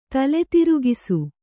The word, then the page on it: ತಲೆತಿರುಗಿಸು: Kannada; tale tirugisu